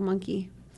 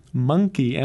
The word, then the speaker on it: Mynci: And then we heard Gorgie's I Got a Monkey. – That's how you pronounce that, eh? – Yeah. – Monkey, M-Y-N-C-I? – I think it's Gaelic or Welsh.